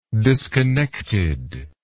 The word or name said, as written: disconnected